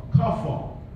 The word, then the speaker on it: kaphar: K-A-P-H-A-R, kafar.